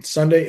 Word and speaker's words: sundae: Sunday is pronounced the same way as Sunday, the day of the week.